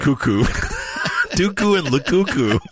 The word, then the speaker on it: Lukaku: What about the decision on Belgium's side not to start Doku and Lukaku? Is that how you say it? – Lukaku.